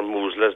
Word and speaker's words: Mousehole: It's a small village at the time called Mousehole, or Moosle, as they pronounce it in Cornwall.